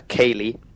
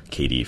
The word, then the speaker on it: ceilidh: We have a dance once a year called a ceilidh, which is spelled C-E-I-L-I-D-H because it's an old Scottish word and it's kind cool.